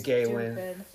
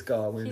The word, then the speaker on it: Gawain: You've been pronouncing it Gawain. It's Gawain.